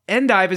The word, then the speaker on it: endive: The rocket-shaped vegetable we call endive is actually pronounced endive.